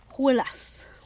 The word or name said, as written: gwulas